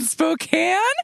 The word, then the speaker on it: Spokane: She's living her life in a city that rhymes with her own name, which is just a picture book waiting to happen. – Pollyann in Spokane?